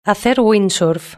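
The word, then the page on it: hacer windsurf: Spanish (Spain)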